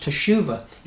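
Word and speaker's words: teshuva (תשובה): It's pronounced teshuva, and it means to return.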